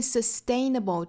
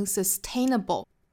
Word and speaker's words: sustainable: I learned that when I made small 后面 sustainable 这个词要注意的点呢，就是中间的这个字母 t 不要读成 sustainable, 应该是 sustainable。